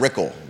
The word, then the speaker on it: Rickle: You had Ann Malik and Brad Rickle, rhymes with pickle.